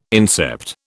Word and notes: incept: British English

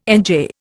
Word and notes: NJ: Indian

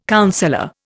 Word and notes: counsellor: Indian

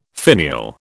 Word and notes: finial: British English

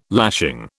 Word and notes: lashing: British English

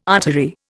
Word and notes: artery: Indian

